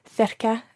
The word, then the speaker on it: cerca: Yeah, last week we learned how to say near to, cerca de, and I was wondering why the first C is pronounced th, but the second C is pronounced k. – Okay, that is a very good question. For our listeners, the word cerca is spelt C-E-R-C-A. So you've got a C at the beginning and then a C later in the word, and they're both pronounced differently.